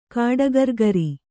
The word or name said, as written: ಕಾಡಗರ್ಗರಿ